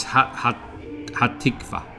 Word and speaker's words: Hatikva: It's Hatikva. Hatikva, but please correct our pronunciation, by the way, in the... – Hatikva, and the translation is hope.